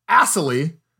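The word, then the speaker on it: Assalley: Iowa State barely wins 16-10, if not for their field goal kicker, Connor Assley, but spelled like Ass Alley.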